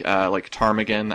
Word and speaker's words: ptarmigan: So these are words like ptarmigan, that has a silent P, oscillation, which has a silent C, just, you know, words like that.